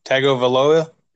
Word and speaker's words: Tagovailoa: Tagovailoa. – Yeah, I think you just said it right right there.